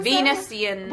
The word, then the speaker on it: Venusians: Never understood how to pronounce Venusians. Is how I was. – Venusians. – What were you saying? I was almost saying Venetians. Then I remember there's people from Venice.